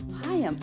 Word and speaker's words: Higham: Higham. Like, hi, I'm Haley.